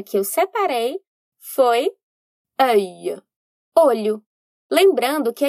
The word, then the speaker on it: œil: Bom, œil se escreve O-E-I-L. Aqui, encontramos duas vogais juntas que, no fim, viram uma vogal só, que é o O.